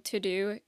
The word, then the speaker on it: Teuxdeux: I will say my favorite website is called To-Do. It's spelled T-E-U-X-D-E-U-X.